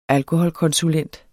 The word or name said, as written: alkoholkonsulent